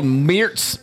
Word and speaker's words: Meerts: It's called Meertz from Funk Factory Gooseria. – How do you spell it? – M-E-E-R-T-S.